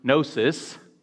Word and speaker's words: gnosis: Often in the New Testament, the word gnosis, spelled with a G or transliterated with a G, N-O-S-I-S, is used when speaking of knowledge.